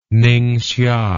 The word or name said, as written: ningxia